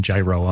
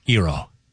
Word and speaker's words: gyro: Because I was doing gyro on purpose to just be a jerk. But it's Iro. – Yeah. – Iro.